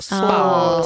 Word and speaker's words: Spaw: Och då ingår allt från ett lyxigt spa som då uttalas, eller skrivs S-P-A-W, så spa, spa.